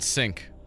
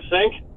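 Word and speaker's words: Cink: Stuart Sink. – Stuart Sink, David Duvall, Tiger Woods, or Vijay Singh? – Stuart Sink.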